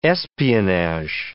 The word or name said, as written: espionage